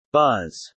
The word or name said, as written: buzz